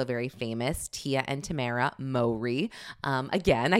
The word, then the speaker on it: Mowry: It's pronounced Mowry, actually. You learn something new every day. I always said his name is Mowry.